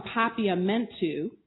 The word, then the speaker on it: Papiamentu: So before I give the closing, on the flyer, an Afro-Caribbean in the Nazi era, from Papiamentu, you said it correctly, to German.